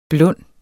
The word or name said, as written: blund